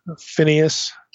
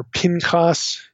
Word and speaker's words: Phinehas: We should mention Phinehas. Okay, that's the way most of us would pronounce this. Phinehas or Pinchas in Hebrew.